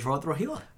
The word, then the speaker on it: Rohit Rohila: I had, there's one girl I was seeing who said it always sounds like I'm saying Rohit the Rohila. Like when I say my name fast.